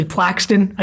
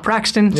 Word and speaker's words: Plaxton: Also, just want to, like, point out that it's actually Plaxton, I just realized, and not Praxton, so we've been saying it wrong the entire time.